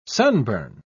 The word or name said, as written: sunburn